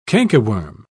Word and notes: cankerworm: British English

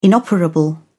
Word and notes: inoperable: British English

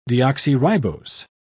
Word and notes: deoxyribose: British English

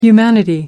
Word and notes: humanity: American English

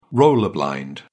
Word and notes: roller blind: British English

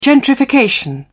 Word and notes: gentrification: British English

